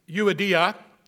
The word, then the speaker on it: Euodia: I entreat Euodia and Syntyche to agree in the Lord.